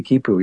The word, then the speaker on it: quipu: You write that they had something called a quipu. Am I pronouncing that right? – Right, the quipu, yeah.